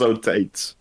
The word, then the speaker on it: Otates: And the last day does contain the longest stage of the day, which is the, going to mess up the pronunciation, Otates. – No way. – At 35 kilometers. – No chance, it's Otates. – Otates. Andrew Otates. – O-T-A-T-E-S.